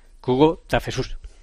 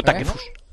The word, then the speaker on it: Takefusa: Y entonces dice que se dice... Kubo Takefus. – ¿Cómo? – Kubo Takefus. – ¿Takefus? – Jesús. – ¿Y por qué? – Kubo Takefus. – Pues yo creo que mañana Lama lo pronuncia así todo el partido. – El problema es pero que hay que decirlo rápido, Takefus.